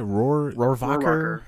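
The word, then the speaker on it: Rohrwacher: How do you say it? – Rohrvacher.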